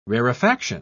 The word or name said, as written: rarefaction